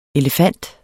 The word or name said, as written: Elephantidae